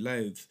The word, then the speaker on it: Lugh: You might remember that last episode we told a story of how the Dagda created the plain of Mirtima in County Louth. Well, that county gets its name from Lou, and his name is spelled L-U-G-H, but in the modern Irish that would be Elufada, which is the name of the county, County Louth, Oscailge.